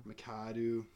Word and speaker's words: McAdoo: Yeah, so Ben McAdoo or McDo or McAdoo. I'm not sure how to pronounce his name. – McAdoo, whatever it is.